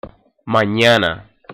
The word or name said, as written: mañana